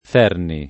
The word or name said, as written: Ferni